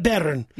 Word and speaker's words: bairn: How do you say it? – A baron. Yes. – Like a baron. Like no. – A red baron. – B-A-I-R-N.